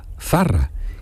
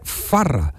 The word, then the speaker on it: Zarra: Matías Prat, es de Palma del Río, de Córdoba, y entonces no pronuncia de Villa del Río, perdón, no pronunciaba la Z porque se seaba. Entonces Zarra es con Z, Zarra, y él gritó y al mundo entero el segol de Zarra y decía Farra porque cambiaba la Z por la F.